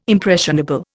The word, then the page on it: impressionable: Indian